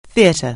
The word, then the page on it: theatre: anglais